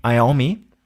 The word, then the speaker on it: Iommi: Was dabei, finde ich, so eine Schlüsselfigur ist, ist der Gitarrist Tommy Iommi. Spricht man so aus? – Ja.